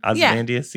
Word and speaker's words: Ozymandias: Am I saying that right? Ozymandias? – Yeah. Yeah, probably. Yeah.